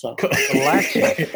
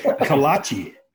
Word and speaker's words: kolache: Kolache. Kolache.